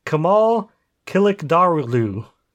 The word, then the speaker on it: Kemal Kılıçdaroğlu: His name is Kemal Kılıçdaroğlu.